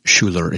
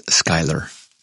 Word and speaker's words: Schuyler: I have been notified that that really good Bible that I have, that deluxe Mamba Bible that I have, it's not Schuller, it's Schuyler.